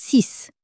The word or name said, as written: six